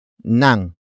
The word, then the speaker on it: nang: The next word is nang, which is spelled as ng.